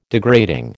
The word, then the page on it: degrading: American English